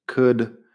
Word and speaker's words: could: Would, could y should tienen la misma vocal corta, es como una U corta, que no existe en español, y luego la L como si nada. Así que no intentes pronunciar una OU en medio porque es U. Uh, la L, nada. Would, could, should. Si dejas de pronunciar la L, ya estás a mitad del camino ahí.